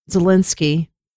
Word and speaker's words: Zelensky: President Volodymyr Zelensky.